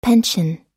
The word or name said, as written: pension